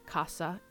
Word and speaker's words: casa: But if I said them using the distinción pronunciation, you can tell the difference between the words very easily. So the word for house is casa, spelled C-A-S-A, and the word for hunting is caza, spelled C-A-Z-A.